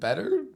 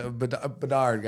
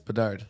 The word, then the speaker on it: Bedard: They're like, yeah, how do you say it? Better? Bedard, guys. Bedard.